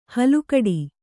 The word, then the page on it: ಹಲುಕಡಿ: halukaḍi